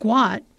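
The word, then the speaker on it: Guot: In 2005, Bona and Monica Gout, pronounced Guat, sought to leave war-ravaged South Sudan.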